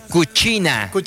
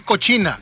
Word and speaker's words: cucina: A la cucina. – ¿Lo venías escuchando? – Sí, no, pero es que así se pronuncia en italiano, así sería lo ideal, porque CC, o sea, es cuchi.